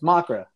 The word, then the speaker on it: Macra: Long A or short A? Is it MACRA? It's MACRA.